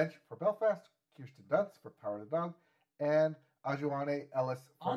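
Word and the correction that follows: Aunjanue: Anjanou. You say it like the word.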